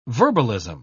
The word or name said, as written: verbalism